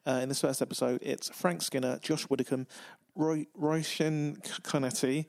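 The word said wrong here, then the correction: Róisín Conaty: It's, it's Roisin Conaty and Romesh Ranganathan but easy for you to say.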